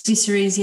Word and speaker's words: Viserys: Viserys, yeah.